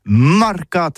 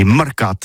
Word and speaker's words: marcat: Eh bien, dis ça à tous, mais le mercat de l'Uni ou le mercat des rebelles, mais en gascogne toulousain, c'est prononcé sur les marcat avec un A. Marque. En gascogne toulousaine, bah, du côté, par exemple, de l'île des Baches, c'est l'île Jourdain, pour parler du marché, vous prononcez les marcat.